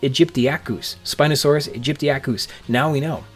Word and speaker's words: aegyptiacus: All my life, as a courtesy to Jurassic Park 3, I've been mispronouncing and misreading Spinosaurus aegyptiacus. It wasn't until terrific guest from episode 56 Jingmei O'Connor was talking about the new mount at the Field Museum when she pronounced it aegyptiacus, and also correctly, that I noticed how it's actually said.